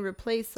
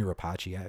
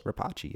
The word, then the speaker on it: Rapace: I go Nomi Rapace. Everybody pronounces Rapace. Yeah. – Is that it? – I don't know, but that's what I go with. – Rapace. – She's European, so I figured that would be the way.